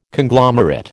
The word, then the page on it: Conglomerate: American English